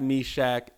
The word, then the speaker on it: Meshach: I'm a butcher it, but I know how to say it. It's Shadrach, Meshach, and Abednego.